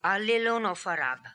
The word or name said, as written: Alillonofarab